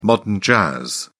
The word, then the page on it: modern jazz: British English